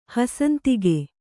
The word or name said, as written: ಹಸಂತಿಕೆ